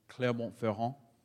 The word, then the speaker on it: Clermont-Ferrand: but in Clermont-Ferrand. No problem. To his credit, it is spelled F-E-R-R-A-N-D. But with most French words, you don't say the last two or three letters.